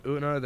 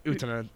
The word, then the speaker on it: Utena: There's the dude from Una, the green-haired dude who just shows up during dinner to challenge Una to a duel. There's literally... – Utena.